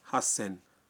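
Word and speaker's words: ہس سین (八千): جی بالکل ٹھیک، لیکن یہاں بھی کچھ اعداد کا معاملہ مختلف ہے۔ 3000 کو کہتے ہیں سان زین، اور 8000 کا تلفظ ہے ہس سین۔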